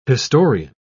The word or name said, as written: historian